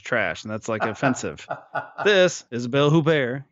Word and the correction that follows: Huppert: you know, Huppert. – Thank you for pronouncing it right the first time.